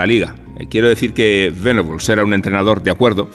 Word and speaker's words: Venables: ahora me Igual que se pronuncia vegetables y no vegetables, es más adecuado pronunciar venables que no venables. Aunque eh, buena parte del debate español se resolvió llamando a Terry Venables, tal cual se leía, venables.